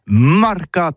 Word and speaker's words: marcat: Eh bien, dis ça à tous, mais le mercat de l'Uni ou le mercat des rebelles, mais en gascogne toulousain, c'est prononcé sur les marcat, avec un A, marque.